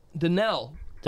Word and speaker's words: Danelle: Danielle, oh no, wait, Danelle. Umstead, am I saying it right? – Yeah.